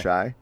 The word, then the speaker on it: Shai: Number three, I have Shai Gilgeous-Alexander. – That's pretty close. – Oh, okay. – I've heard it pronounced Shay or Shy. I stay okay. – Shy.